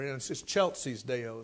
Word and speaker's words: in excelsis Deo: Gloria in excelsis Deo, or in excelsis Deo is how it's pronounced.